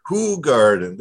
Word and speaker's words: Hoegaarden: I had some Hoegaarden, and speaking of Hoegaarden, is that the proper pronunciation of Hoegaarden? I know you're in Belgium, and it's a Belgian beer, so is it Hoegaarden? – Hoegaarden, there we go.